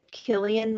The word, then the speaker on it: Cillian: Is it Cillian Murphy? – Yeah, it is.